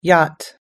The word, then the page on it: yacht: American English